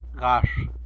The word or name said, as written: gax